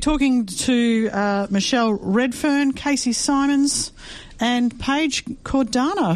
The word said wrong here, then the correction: Cardona: who we've had on the show. – Mate, you've been doing so well. – Cardona. – Cardona.